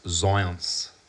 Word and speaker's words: Zajonc: Surname, you read it and it sounds, it looks like Zajonc. Z-A-J-O-N-C, I'm pretty sure, but it's pronounced Zionce. – Wow. Robert Is it Zionce, like East European or something? – I think it's French.